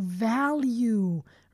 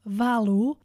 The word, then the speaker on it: value: Here we have that verb again, to value. Remember, don't pronounce it like this, value. No. Always pronounce it like this, value, to value. Here's a trick. It's like val and then you.